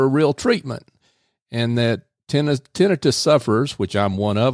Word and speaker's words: tinnitus: Did yes. I get that pronounce, pronunciation right? – You pronounced it like a physician would pronounce it. That's because I've been hanging around a physician. – Um, as opposed to tinnitus. – Yeah, yes, which is what I used to pronounce it as.